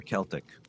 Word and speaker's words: Celtic: By the way, do you say Celtic or Celtic? I say Celtic. Me too. It's a hard C. Yeah. It's a hard C. – It's um, a hard, two hard C's there.